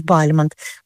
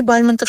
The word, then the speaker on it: Бальмонт: Правильное ударение Бальмонт. Ну, хотя и Бальмонт, и Бальмонт, возможно. Почему? Да все очень просто. Когда Бальмонт уехал во Францию, решил называть себя на французский манер.